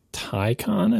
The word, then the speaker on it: Taycan: Yeah, they want you to say Taycan, I think. It's definitely Ty. The second syllable, I'm pretty sure, is con.